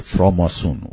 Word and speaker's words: فراماسون: یه شبکه بسته‌ای داشتن، یه حلقه بسته‌ای از بنایان یا معماران، از اونجا شروع میشه این واژه، اون حلقه بنایان آزاد، فریمیسن که تلفظ چیزش میشه فراماسون، اون تلفظ فرانسویش.